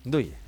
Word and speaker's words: Ndoye: Ndoye, Ndoye con l'accento sulla E.